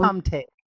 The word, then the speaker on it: Tomte: It is the gnome, or the Tomte, if I'm pronouncing it correctly. Probably not, because I'm not Scandinavian. So Tomte. – There you go.